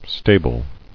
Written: Stabled

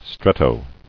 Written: Stretto

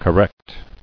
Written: Correcting

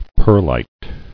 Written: Perlitic

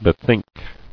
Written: Bethinking

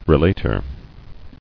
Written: Relator

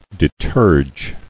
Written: Deterging

